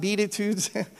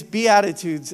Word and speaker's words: Beatitudes: It's not Beatitudes. It is Beatitudes. Everybody say it with me. Beatitudes. Amen. Yeah, it's like these are the attitudes that you should be.